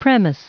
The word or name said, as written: premise